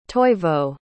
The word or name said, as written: toivo